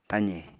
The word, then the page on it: taze: apatani